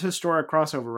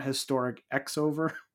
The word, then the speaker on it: Historic X-Over: So I'm like, it is Historic Crossover, right? Not Historic X-over. – You'll see a lot, like, in Japanese stuff with either the X is just not pronounced at all, or the X is cross.